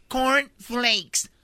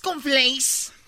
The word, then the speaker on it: corn flakes: Perfecto, es corn flakes. No es con flakes. Y, no es y ese es un y, no se dice con flakes al cereal, es cereal.